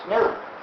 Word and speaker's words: gnu: It's because, according to the dictionary, the G is silent, and it's pronounced new. So anytime you want to write new, you can write it G-N-U, and you have a joke.